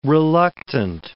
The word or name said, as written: reluctant